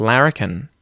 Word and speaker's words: larrikin: Or the word larrikin, for example, meaning a mischievous person, which came from the word larking, meaning getting up to mischief, but with Irish pronunciation sounded like larrikin.